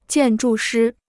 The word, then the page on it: 建筑师: jiàn zhù shī